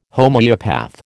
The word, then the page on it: Homoeopath: American English